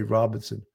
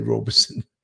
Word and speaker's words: Robeson: White Lightning 00, exactly called Robeson County, but it's spelled Robeson. We all say Robinson. I thought I did say Robeson, like Paul Robeson, the singer, songwriter.